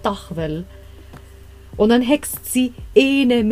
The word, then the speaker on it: tahvel: Auf estnisch heißt die Tafel nämlich Dachwell. Also kommt tatsächlich aus dem Deutschen und wird, also wird geschrieben T-A-H-V-E-L.